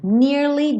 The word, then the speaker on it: nearly: When the day is nearly done, nearly done. Tem que ter esse Y no som do nearly, tá? tá? É uma palavra de duas sílabas, nearly.